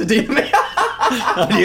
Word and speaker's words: Idina Menzel: It's Idina Menzel.